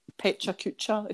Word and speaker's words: Pecha Kucha: But it's like pronounced as Pecha Kucha, if you're reading it out.